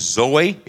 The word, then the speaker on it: zoe: The word that I would pronounce it as is Zoe. It's Z-O-E.